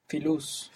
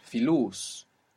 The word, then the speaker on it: filus: Filus. – Filus. Where is the accent? – In the last syllable.